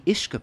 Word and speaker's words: uisce: The Ishka part of Ishkabaha just means water, spelt U-I-S-C-E or G-E, which in English pronunciation would be something like whiskey.